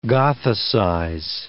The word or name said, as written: gothicize